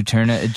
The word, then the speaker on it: Juturna: but it's Juturna? – I don't know. I just looked it up. – Juturna. J-U-T-U-R-N-A.